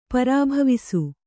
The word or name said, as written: ಪರಿಭವಿಸು